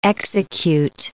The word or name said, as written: execute